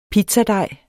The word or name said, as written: pizzadej